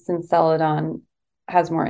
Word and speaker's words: Celadon: It's called, what, Celadon? – Celadon, yeah.